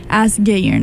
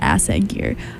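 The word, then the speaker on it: Ásgeir: And then King and Cross from Askeyer, which I realized today I've been pronouncing in my head the wrong way for like the year and a half that I've been listening to this song. Askeyer, not Assegyer.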